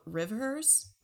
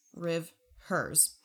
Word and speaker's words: Rivhers: And then I just want to spotlight an up-and-coming artist that I found this week, and they're called Rivers, like R-I-V-H-E-R-S, Rivers.